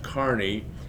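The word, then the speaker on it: Kearny: Then a fellow by the name, his name is spelled Kearney, but it's pronounced Kearney,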